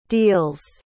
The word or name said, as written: deals